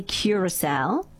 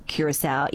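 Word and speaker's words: Curaçao: The country is definitely Curacao. However, the drink, blue Curacao, is sometimes pronounced as Curacao.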